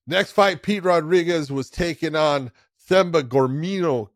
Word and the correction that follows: Gorimbo: Gorimbo? – Say it wrong. Gorimbo.